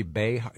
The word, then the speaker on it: Behar: Joy Behar. Is it pronounced Behar? – Mm-hmm. – Okay. That's pronounced.